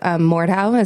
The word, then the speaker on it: Mordhau: I would have loved to try to Mordhau.